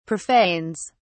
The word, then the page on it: profanes: British English